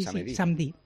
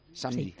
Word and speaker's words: samedi: Samedi. – Samedi. – Sí. No digas la E. Así, la E te la comes un poco. Samedi.